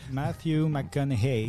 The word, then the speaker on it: Matthew McConaughey: Matthew McConaughey.